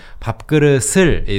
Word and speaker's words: 밥그릇: 밥그릇을 is the correct pronunciation. Uh-huh. But when people speak fast, they often just say 그릇.